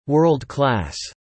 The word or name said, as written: World Class